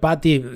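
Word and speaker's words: Pathé: Y el de Thierry Patty, que este será menos conocido, pero era descendiente de la familia Patty, se pronuncia más o menos así, creo, pero se escribe P-A-T-H-E, o sea, una familia francesa pionera de la cinematografía.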